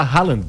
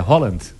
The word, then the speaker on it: Haaland: O centroavante, a gente fala Haaland, mas a pronúncia é Holland.